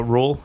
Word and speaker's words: Rhule: Um, and then I, there's links between Baylor's head coach uh, Rhule. I think that's how you pronounce it. R H U L E.